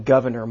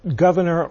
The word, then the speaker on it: Gouverneur: Well, I actually probably should have pronounced it Governor or Governeer, as Abigail Adams said. It's spelled Gouverneur, but it may have been pronounced Governor.